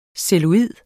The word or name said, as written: CELLULOID